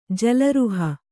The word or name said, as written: ಜಲರುಹ